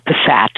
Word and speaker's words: PASAT: Is it pronounced, is it pronounceable, PASAT? – Well, it's a psychobiological approach to sex addiction treatment. So we could call it PASAT, I suppose.